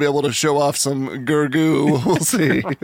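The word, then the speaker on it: Gergö: It's Gábor, his mom, his dad, and then his brother, Gergo, G-E-R-G-O, with a double dot over the O. And I looked up. – That makes it Gergo. – I looked up how to pronounce it, and it was like Gergo.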